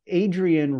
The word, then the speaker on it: Adrian: In fact, Adrian rhymes with Arian. He's It is very close. – Blue eyed, well, it's a soft rhyme, but yeah, blue eyed, blonde hair.